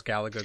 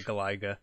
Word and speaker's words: Galaga: That sounds like my gaming AI professor that calls Galaga Galiga. – Oh, pain. Did anybody tell him he's pronouncing it wrong?